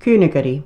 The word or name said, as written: KYNAGARRY